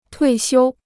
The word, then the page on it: 退休: tuì xiū